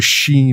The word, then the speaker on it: sidhe: It's actually spelled S-I-D-H-E. People like myself who have seen that word in the past pronounce it Sidhe, but it's pronounced she basically.